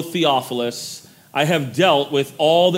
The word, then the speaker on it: Theophilus: That name that you see there is Theophilus. That's how you say that.